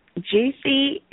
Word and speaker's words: Jewcee: And Jewcee is spelled J-E-W, and then C-E-E.